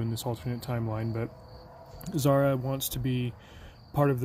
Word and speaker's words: Zara: Zara. Spelled Z-A-R-A, like Sarah, but with a Z.